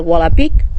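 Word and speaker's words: Volapük: que es el Wolapik, o el Volapuk se escribe.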